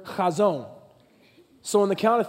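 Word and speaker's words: chazon (חזון): One, two, three, chazon. Now listen, if you're saying this correctly, the person in front of you should feel a little moisture on the back of their head when you get it out.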